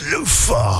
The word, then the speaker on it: Lufa: Ray, say it properly. It's Lufa. You have to say it like that when you play him.